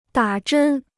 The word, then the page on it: 打针: dǎ zhēn